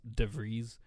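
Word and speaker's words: de Vries: It's actually pronounced DeVries, but...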